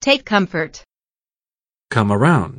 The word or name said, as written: take comfort